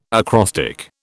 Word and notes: acrostic: British English

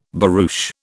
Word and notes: barouche: British English

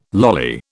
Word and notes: lolly: British English

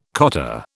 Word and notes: cotta: British English

